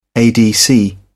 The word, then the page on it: adc: British English